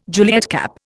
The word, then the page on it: Juliet cap: Indian